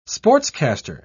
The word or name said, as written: sportscaster